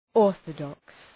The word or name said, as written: orthodox